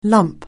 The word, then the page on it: lump: anglais